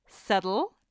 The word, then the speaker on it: subtle: Settle, because where does the B go?